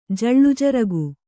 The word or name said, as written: ಜಳ್ಳುಜರಗು